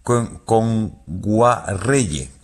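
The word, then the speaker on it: Ngwarreye: Emily Kame Nguayere, lo he dicho mal. – Emily Kame. – Con Guarreye. – Emily Kame con Guarreye, sí, sí.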